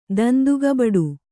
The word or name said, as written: ದಂದುಗಬಡು